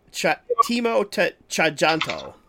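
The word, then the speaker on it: Timo Tjahjanto: Timo Tjajanto. – Timo, T-I-M-O? – T-I-M-O. And T-J-A-H-J-A-N-T-O.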